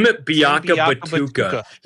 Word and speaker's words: Biakabatuka: Tim Biakabatuka.